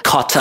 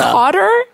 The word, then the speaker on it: cotta: Cotta. – Cotter? – Cotta. – Can you spell it? – C-O-T-T-A. Cotta. – Cotta, not cotter.